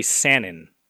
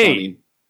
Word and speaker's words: Sannin: Who was the first to die in the legendary Sannin? Is it A... – Sannin. – What? Did I say that wrong? – Sannin. – Sannin? Okay.